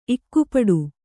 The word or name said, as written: ಇಕ್ಕುಪಡು